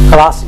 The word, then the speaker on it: Colossae: Colossae.